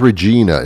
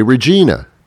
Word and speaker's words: Regina: The name of the town is, I thought it was Regina. It's R-E-G-I-N-A. And I would say Regina. I was corrected. The proper pronunciation of that town is Regina.